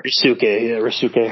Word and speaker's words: Ryosuke: Ryosuke Hamaguchi.